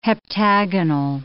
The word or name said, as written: heptagonal